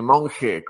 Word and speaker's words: Monge: Eh, monje, como si fuera con J, solo que se escribe con G.